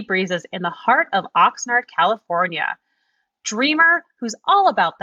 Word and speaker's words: Oxnard: Oxnard.